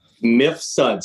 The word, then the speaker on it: Mifsud: Mifsud. Split it right in half. M-I-F-S-U-D.